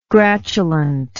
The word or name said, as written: gratulant